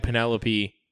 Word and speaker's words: Penelope: Now, mind you, I have had Penelope barrel strength.